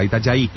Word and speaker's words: Itajaí: En Brasil se pronuncia como Y, Itajaí.